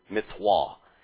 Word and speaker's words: mitzvah: Of the mitzvah in modern Hebrew or Ashkenazi pronunciation, mitzvah, is how I'd pronounce it.